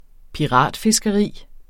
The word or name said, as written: piratfiskeri